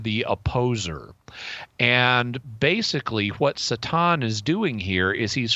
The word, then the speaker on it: Satan: So he's got he's the king, he's got his princes there, and in comes this figure known as Satan, or to pronounce it the Hebrew way in this case, Satan.